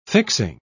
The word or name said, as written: fixing